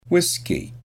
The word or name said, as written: whisky